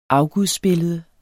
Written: afgudsbillede

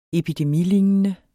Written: epidemilignende

